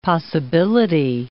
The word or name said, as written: possibility